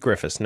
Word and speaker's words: Griffis: Keith Griffis, no Mick, but I like that ad.